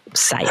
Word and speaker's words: Psy: Psy. – Psy. ¿Cómo se escribe? Psy. – P-S-Y.